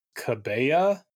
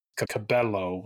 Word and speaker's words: Cabello: which was accepted initially and then later ruled incorrect because her name is Cabello or you know Camila Cabello, Cabello, yeah. I guess. And if you're going to pronounce the the double L that way, it would still be Cabello. – Uh, yeah, Cabella. I assume it. I assume the final vowel was the problem. Yeah, he pronounced it as if it was maybe an A or something, and it's yeah. an O.